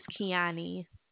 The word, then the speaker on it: Keoni: My name is Kiani.